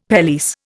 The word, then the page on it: pelisse: Indian